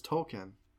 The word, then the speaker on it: Tolkien: It's a Tolkien thing, and that kind of are you saying his name right? Tolkien. – Tolkien. – I think it's Tolkien. – Tolkien. Tolkien. J.R.R. Tolkien.